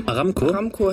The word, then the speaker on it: Aramco: Aramco, hätte ich auch gesagt.